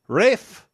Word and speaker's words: Rafe: Rafe.